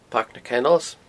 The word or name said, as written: Pairc na Kennels